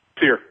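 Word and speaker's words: SERE: I guess, is that how you pronounce it, SEER? – SEER, that's right.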